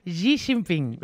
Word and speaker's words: Xi Jinping: Aviam, digue-ho. – Digue-ho. – Xi Jinping. – Xi Jinping, bueno. – No sé si es pronuncia així, ja us ho dic, eh?